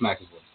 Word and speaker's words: McAvoy: I'm not sure if I'm pronouncing it, but it's James McAvoy or McCabe. – I think it's McAvoy.